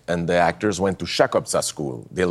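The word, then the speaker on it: Chakobsa: There's a language, the Fremen language, Chukotka. Am I saying that correctly? – Absolutely. Chukotka. Yeah.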